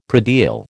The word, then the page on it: predial: American English